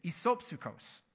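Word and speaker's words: isopsychos (ἰσόψυχος): It's isopsychos.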